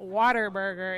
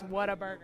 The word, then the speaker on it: Whataburger: So it's not Water Burger. It's What a Burger.